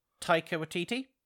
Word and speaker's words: Taika Waititi: Taika Waititi? – No, I think he said it right.